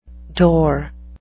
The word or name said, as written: dour